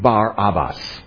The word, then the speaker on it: Barabbas: We might, if we were Hebrews, we would more accurately pronounce his name Bar-Abbas.